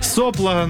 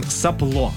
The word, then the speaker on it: сопло: Далее, слово, которое, конечно, мы каждый день используем в своей жизни, да, сопло, ну, часто, наверное, так и произносим, сопло, правильное ударение.